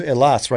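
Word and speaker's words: Elas (Ελλάς): It's like you know where I'm going to go to Elas right now. It's like where's that? It's Greece. But that's how you pronounce it in Greek.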